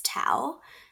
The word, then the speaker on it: Thao: Name is actually pretty interesting too, because my Vietnamese name is Tao. It's spelled T-H-A-O, and it means obedient.